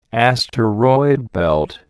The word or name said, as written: asteroid belt